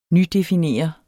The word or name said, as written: nydefinere